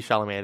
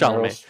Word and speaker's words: Chalamet: Timothy Chalamet, I didn't say his name. Come on. – Yeah. – It's Chalamet.